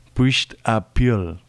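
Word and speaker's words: Puirt à beul: Vamos a intentarlo después de habernos asesorado de este género en gaélico escocés, que es Puisht a Piul. Se escribe Puisht a Beul.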